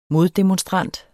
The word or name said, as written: moddemonstrant